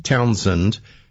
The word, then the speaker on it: Townsend: Mike Townsend. Bob said Townshend because it looks like that, but it's a silent H.